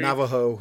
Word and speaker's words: Navajo: Then it talks about Rambo's half Navajo, am I saying that right? Ancestry? – Navajo. – Navajo, there you go.